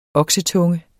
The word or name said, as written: oksetunge